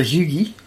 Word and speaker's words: hygge: So apparently it's a Danish word pronounced hygge. – Disagree. – Which I would not have pronounced it like that.